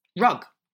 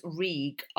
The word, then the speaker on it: Rhug: I'm using an exceptionally nice face mask from, it's called R-H-U-G is the make. – What? Rug? – No, it's pronounced Reeg, R-E-E-G.